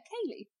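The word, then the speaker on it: ceilidh: a Cayley, which is like a party where everybody, she said.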